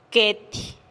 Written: ketih

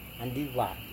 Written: andiva

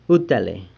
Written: utale

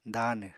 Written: daanw

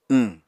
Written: wñ

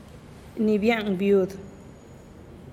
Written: ni bia'an viud

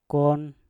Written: kwon

